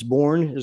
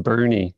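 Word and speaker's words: Boerne: I believe it's Bourne. Is that correct? In Texas? – Yeah, it's pronounced Bernie, although it looks Bernie.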